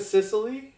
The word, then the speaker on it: Cicely: Is it Cicely? – Yeah.